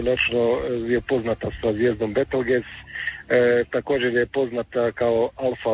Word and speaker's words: Betelgeuse: Snimljanje pratitelj zvijezde, e sad da li ću ovo dobro izgovoriti, Betelgeuse ili Betelgeuse? – Betelgeuse. Neki članovi našega zovu Betelgeuse. Pa Betelgeuse, dobro, isto, lakše pamtivo.